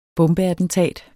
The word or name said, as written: bombeattentat